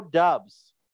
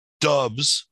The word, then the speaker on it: Doubs: It's spelt that way, but it's not pronounced that way, Eric. – It definitely is pronounced that way. – Doubs. – I'm pretty sure it's Doubs. – Doubs. – I think it's spelt Doubs, but it's pronounced Doubs. – No, it's spelt Doubs. D-O-U-B-S. – Doubs would be D-U-B-S. – No, the O is silent.